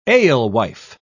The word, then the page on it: alewife: British English